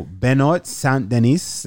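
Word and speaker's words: Benoît Saint-Denis: And I'm like, Yo, Benoit Saint-Denis, that's how you say his name, right? Mm-hmm.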